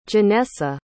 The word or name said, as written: genessa